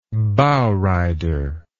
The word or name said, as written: bowrider